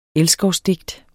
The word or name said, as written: elskovsdigt